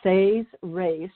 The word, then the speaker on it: Seres: They are titled The Says Race, which is spelled S-E-R-E-S, The Says Race Agenda, and The Emerald Doorway, Book 1 of the Parallel Time Trilogy.